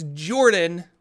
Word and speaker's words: Jorphdan: My name is Jorphdan, with a silent PH in the middle, and I am joined always by my wonderful co-host, Sir Lucien, over at Sir Lucien Gaming.